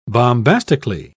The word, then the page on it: bombastically: British English